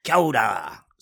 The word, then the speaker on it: kia ora: It's like Rita Ora. – How do you say it? – It's kia ora. So it's like, you just kind of mash it all together.